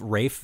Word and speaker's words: Ralph: If you pronounce Ralph Rafe, then you can pronounce your last name however the hell you want, as far as I'm concerned.